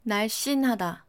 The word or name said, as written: 날씬하다